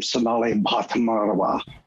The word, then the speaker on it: Bhatmarwa: She's a PhD psychologist named Sonali Bhatmarwa. Her emphasis is in neuropsychology. – Good pronunciation, by the way, on that aspirated B at the beginning of her name. That's a difficult sound for Americans.